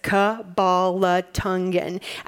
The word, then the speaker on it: Kabbalatungan: And so for the online viewers, it's very phonetic. It's Kabbalatungan, and you can practice that at home.